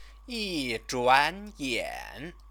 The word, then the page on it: yizhuanyan: chinese